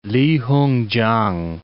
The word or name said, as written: Li Hung-chang